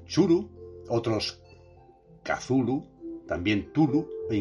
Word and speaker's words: Cthulhu: sería algo así como Cthulhu, con una primera sílaba muy gutural y viscosa. La mejor aproximación que se puede hacer es gruñir, ladrar o toser. Las muy malamente formadas sílabas, sigue diciendo Lovecraft, Cthulhu, con la punta de la lengua firmemente pegada al cielo de la boca.